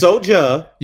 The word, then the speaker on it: Soulja: Yeah, you it's Soulja. Say it right.